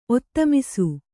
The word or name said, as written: ಒತ್ತಮಿಸು